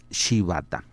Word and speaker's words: Shibata: es eh, T-O-S-H-I-Y-U-K-I, Toshiyuki, y el apellido es Shibata, S-H-I. B-A-T-A, Toshiyuki Shibata.